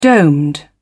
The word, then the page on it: domed: British English